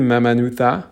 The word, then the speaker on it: Mamanuca: Il y a aussi les îles Mamanuta. Ça s'écrit M-A-M-A-N-U-C-A. Mais le C se prononce en fait comme un T-H en anglais un petit peu. Donc les îles Mamanuta.